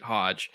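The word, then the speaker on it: Hoge: Hodge, which I actually looked it up a couple weeks before the draft, because I thought it was Hogue as well. It's, I guess he pronounces it Hodge.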